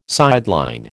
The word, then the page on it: sideline: American English